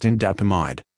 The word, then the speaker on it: indapamide: Sustained release tablets containing indapamide, pronounced indapamide.